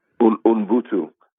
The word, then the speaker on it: Ubuntu: Tell us about the Ubuntu, I hope I'm saying that right, Ubuntu initiative. – Ubuntu. So Ubuntu okay.